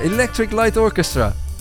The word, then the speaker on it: Electric Light Orchestra: Electric Light Orchestra.